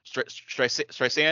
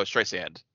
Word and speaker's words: Streisand: Barbara Streisand. And, like, the other, like, the main kid's just, like, Streisand? And he's like, no, Streisand.